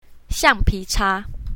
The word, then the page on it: xiang4pi2ca1: mandarin